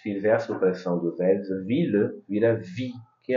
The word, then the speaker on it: ville: Tem uma grande exceção a esse encontro do ILLE, que é a palavra ville, la ville de Paris.